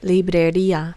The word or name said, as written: librería